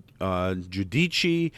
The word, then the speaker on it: Giudice: Salvatore Martone is the proper pronunciation of Teresa's last name, Giudice or Giudici. I swear it's the latter.